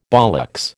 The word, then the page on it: bollocks: American English